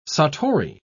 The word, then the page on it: satori: British English